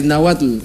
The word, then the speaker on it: Náhuatl: Es que es difícil de pronunciar porque es N-A-H-U-T-L. Sí, T-L, sí. Sí. – Náhuatl. Entonces, bien. Es como Tetzaclenalgo, una vaina así, pero dice tla.